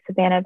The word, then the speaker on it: Savannah: So my name is Savannah Besserill, and I run the company Go Savvy.